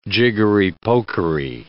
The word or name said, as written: jiggery-pokery